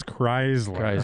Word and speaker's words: Chrysler: Although you pronounce Chrysler wrong. It's Chrysler.